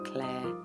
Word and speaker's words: Clare: Or you find the Patreon website and then you can search for me on there, Claire J. Chater. C-L-A-R-E, the letter J for jungle. And my surname Chater is C-H-A-T-E-R.